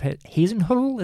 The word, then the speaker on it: Hasenhüttl: I also want to just make a mention of Ralph Hasenhüttl.